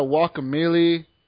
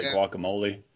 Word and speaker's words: Guacamelee: But I've heard it pronounced all different sorts of things. Like I know someone who calls it guacamole. – Guacamole is what you put on your burrito.